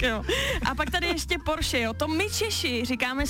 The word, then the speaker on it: Porsche: Všichni říkají Porsche špatně, ale je to opravdu Porsche, takže tady si můžeme jako poklepat na rameno, že to říkáme dobře.